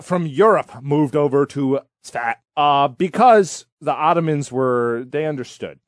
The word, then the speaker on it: Safed: So this guy, was born October 11th, 1542, in Sfat, which is spelled S-A-F-E-D, but it's pronounced like a fart, Sfat.